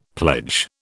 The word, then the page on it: pledge: British English